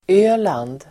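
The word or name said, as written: Öland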